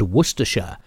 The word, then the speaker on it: Worcestershire: Let's start with Hereford and Worcestershire.